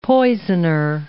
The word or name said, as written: poisoner